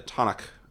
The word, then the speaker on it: Tanakh: and it is guided by the Tanakh, which I may be pronouncing wrong. – I think that's right.